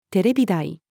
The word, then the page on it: テレビ台: female